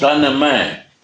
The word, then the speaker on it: Taname: Tanana, how you say it? – Taname.